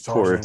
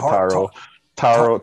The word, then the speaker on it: Taurus: If people care, I'm Taurus. Same as my friend named James. – He's Taurus as well. Taurus, not Taro. Taro's the bubble tea thing.